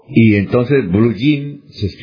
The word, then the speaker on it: blue jean: Entonces ya, por ejemplo, usted encuentra en el diccionario blue jean, así con Y, porque esa es la pronunciación del inglés de la palabra blue hands. Entonces se dice blue jean.